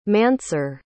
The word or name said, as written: Mansur